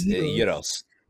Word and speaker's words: gyros: It's like silent G. So it's like it's G-Y-R-O-S. Euros. – Euros.